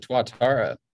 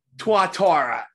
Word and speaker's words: Tuatara: Actually, I actually had press passes to the auto show like 2 years ago, and the Tortora. I forget how to say that. SSA. The launch of that car. – The hypercar, the Tuatara. – Tuatara.